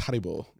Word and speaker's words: Haribo: I believe it's pronounced Haribo.